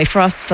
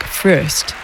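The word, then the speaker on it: Fröst: By the way, Frost's last name is spelled with two dots over the O, an umlaut, so in his native Swedish, it's pronounced more like Frost. But in English, everyone seems to just agree to pronounce it Frost.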